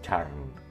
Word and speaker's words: churn: En realidad es churn. Se escribe C-H-U-R-N.